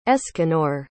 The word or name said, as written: escanor